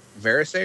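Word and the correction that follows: Varèse Sarabande: or what? – Verez Sarabande.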